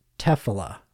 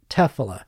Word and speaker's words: tefillah: Now, I don't know if you know this, but the Hebrew word for prayer is tefillah. And I hope I'm saying that right. Tefillah. T-E-F-I-L-L-A-H, tefillah, and which means attachment.